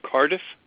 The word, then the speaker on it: Cardiff: The Bank of Cardiff? – Yeah, Cardiff. Sorry, it sounds kind of funny over the phone. It's spelled C-A-R-D-I-F-F, like different.